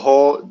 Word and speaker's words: Hull: And Bill Hull, the last name, H-U-L-L, is that correct pronunciation? – That's correct.